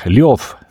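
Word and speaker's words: Ljov (Лев): Õnnitlen kõiki , kes selles helilõigus suutsid ära tunda Lev Tolstoi ehk Ljov Tolstoi , nagu suurmees ise oma eesnime hääldas .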